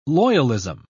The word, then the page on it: loyalism: British English